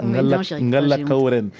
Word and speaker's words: Ngala Kaoren: Alors, je ne suis pas sûre de le prononcer comme il faut. Ngala Kaoren. – Mais c'est ça, Ngala Kaoren.